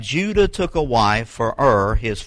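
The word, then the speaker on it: Er: That's is E-R, but it's pronounced U-R, Ur.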